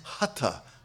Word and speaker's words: hatha: Mispronounced often as hatha. Please don't say that. Okay, it is hatha, hatha yoga. There's no tha in Sanskrit. Okay, so the H is aspirated. Okay, so it's hatha yoga.